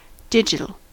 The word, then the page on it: digital: American English